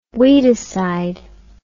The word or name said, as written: weedicide